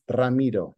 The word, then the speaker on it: Ramiro: Tell me how to pronounce it the right way. – So it's Ramiro.